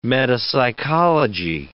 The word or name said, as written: metapsychology